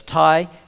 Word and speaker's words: Tai: Tai Whanga is his name. It's spelt T-A-I, that's Tai.